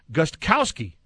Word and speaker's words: Gostkowski: It's pronounced Gostkowski. No, it's not. The K is silent. Isn't it? No, actually, you pronounce everything. – You're the pro. – No. Wait a second. Tim, you're right. I had to sit here and think about it. It's Gostkowski. It's not Gostkowski.